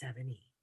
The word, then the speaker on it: Sevigny: But yeah, that's what Instagram, Facebook, Twitter, either under Corey and Sarah or me as Sarah Savini. – Sarah Savini. And how do you spell your last name so people can know? – Sevigny. And so if you really want to pronounce it correctly, it's 7E.